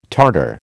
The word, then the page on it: tartare: American English